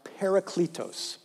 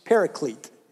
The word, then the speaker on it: parakletos: The ancient Greek word that we translate as comforter is pronounced parakletos, paraklete in English, parakletos.